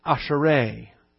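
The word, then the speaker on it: ashrei: Coming out of the Hebrew, asherai. Think Asher.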